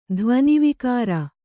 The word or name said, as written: ಧ್ವನಿವಿಕಾರ